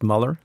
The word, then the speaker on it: Mueller: One small point before I put Ann on the hotspot, and that is, it's pronounced Mueller. It's Bob Mueller.